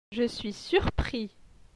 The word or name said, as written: Je suis surpris